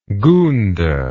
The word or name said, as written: goonda